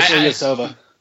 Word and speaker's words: Ilyasova: The guy who to watch out for is Ilyasova. That's how you say his name, right? – Ilyasova.